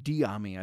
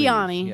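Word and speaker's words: Dyami: Did I say that correctly? – Diami, I believe. – Diami, yes.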